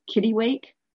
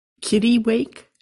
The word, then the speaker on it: kittiwake: And I saw my first kittiwake, a black-legged kittiwake. – Kittiwake, as in wake up, little kitten? – Well, yes. – That's how it's pronounced, kittiwake. – That's my understanding, kittiwake. That's how it's spelled.